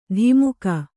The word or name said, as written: ಧಿಮುಕ